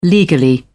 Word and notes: legally: British English

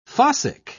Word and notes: fossick: British English

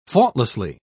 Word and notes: faultlessly: British English

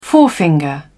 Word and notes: forefinger: British English

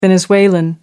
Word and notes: venezuelan: American English

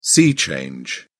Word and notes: sea change: British English

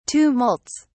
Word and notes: tumults: British English

